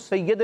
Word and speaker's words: سید: یا کے نیچے کیا ہوتی ہے؟ زیر ہوتی ہے. بچوں یہ درست, اس کا تلفظ سید نہیں ہوتا بلکہ کیا ہوتا ہے؟ – سید.